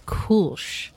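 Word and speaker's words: Kölsch: And they are nice enough on the can, so I was pronouncing this wrong. It is Kolsch.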